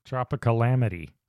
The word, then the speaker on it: Tropicalamity: Tropicalamity. Like calamity.